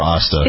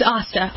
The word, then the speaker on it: Osta: Osta or Asta? – It's Asta. Asta like pasta.